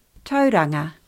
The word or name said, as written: Tauranga